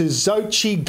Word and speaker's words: Xochitl: the Mirrorball Trophy, that is, and that went to Xochitl Gomez. That's spelt with an X.